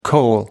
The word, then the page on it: kohl: American English